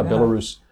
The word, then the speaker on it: Belarus: To do the Belarus, yeah, Belarus, where they, it's actually pronounced Belarus, right? – Yeah.